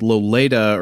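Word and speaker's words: Loleta: In reality, they shot it in a town called Loleda or Lolita. It's spelled like Lolita, but with an E.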